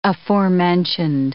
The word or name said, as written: aforementioned